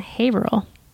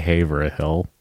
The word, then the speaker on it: Haverhill: You don't say Haverhill. – Haverhill. – Haverhill. That's just what Haverhill. I said. You Haverhill. – Said, you said Haverhill. You threw an extra syllable that's not there.